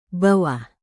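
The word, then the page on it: bawah: indonesian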